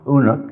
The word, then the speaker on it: Unuk: In southern Aramaic, the name is pronounced Unuk instead of Enoch, but it's the same thing.